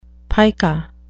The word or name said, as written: pica